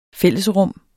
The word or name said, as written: fællesrum